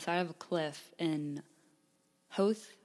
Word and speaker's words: Howth: The locals say Howth, so we'll go with Howth.